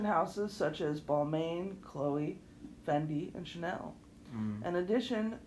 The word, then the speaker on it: Fendi: That's Fendi, not Fenty.